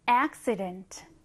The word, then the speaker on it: accident: 이번에는 accident. accident. 강세는 앞에 넣어주시면 됩니다.